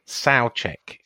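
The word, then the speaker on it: Souček: Souchek is how what is the correct pronunciation. – Well, it might be in Czechoslovakia, but we ain't in Czechoslovakia.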